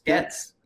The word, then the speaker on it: Goetz: Goetz.